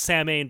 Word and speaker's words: Samhain: How'd no, but it's spelled like it's pronounced Samhain, but Samhain is the Gaelic term for yes.